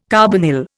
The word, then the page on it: carbonyl: Indian